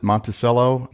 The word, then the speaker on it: Monticello: All the locals pronounce it Monticello. – Monticello. – Not Monticello. So if you go in and you ask people where the town of Monticello is, they know that you're an outsider.